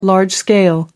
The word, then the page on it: large-scale: British English